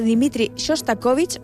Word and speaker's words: Dmitri Shostakovich: Vals de Dmitri Shostakovich, vull repetir-ho perquè crec que m'ha sortit superbé la pronunciació de l'apellido i volia que es notés, o una adaptació del famós Libertango, Astor Piazzolla, que escoltes ara mateix.